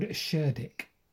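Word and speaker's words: Cerdic: And I've also been talking about the first king of Wessex in around about 580 AD, a man called Sherdic. And I have pronounced that correctly, believe it or not.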